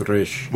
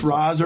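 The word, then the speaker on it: Ra's: And the Arabic, they would pronounce it, I believe, as... – It's Raish. – Well, I think it's Raish. – DC will, like, purposely spell it three different ways in one book, though. Like, right, but for the most part, it's R'A.S. But and it's just Raz or Raish, depending on the culture that's pronouncing it.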